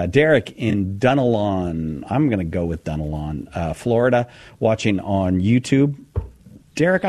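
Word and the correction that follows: Dunnellon: No, actually it's pronounced Dunellen.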